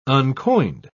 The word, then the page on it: uncoined: British English